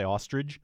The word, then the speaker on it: ostrich: I say ostrich, like ridge, and I'm looking now in the OED, and there's an example. It used to be spelled, and I feel a little better about it, A-U-S-T-R-I-D-G-E. So I feel a little calmer now about the fact that I pronounce it ostrich.